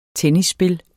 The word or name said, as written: tennisspil